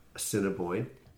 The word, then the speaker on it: Assiniboine: Your album of last year, Assiniboine. – You got it. – And The Red. We worked on my pronunciation earlier. – You win a prize.